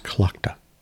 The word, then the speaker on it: Tlachtga: So Klaka is TLACHTGA. It's pronounced Klaka.